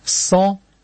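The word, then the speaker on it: sang: По-французски кровь будет «сан», но это я прочитал, а пишется «санг» на конце буквы «г», и так.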